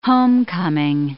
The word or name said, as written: homecoming